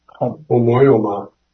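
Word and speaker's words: homoioma: But that likeness of his resurrection, and I tried to pronounce this Greek word homoomia, it's H-O-M-O-I-O-M-A. Any takers on that? It's Homoyoma. – Yes, Homoyoma. Thank you.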